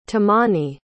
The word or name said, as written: temani